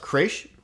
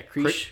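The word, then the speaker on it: crèche: They do, I believe it's pronounced Creche. – Yeah, Creche. – Creche. – Yeah. – C-R-E-C-H-E. – With an apostrophe above the E, I think, the first yeah. E. I'm not 100% sure.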